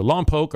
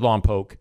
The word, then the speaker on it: Lompoc: So Lompoc or Lompoc, I've heard it pronounced both, but I stick with Lompoc. And I think that's the official way to say it.